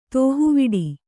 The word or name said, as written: ದೇಹವಿಡು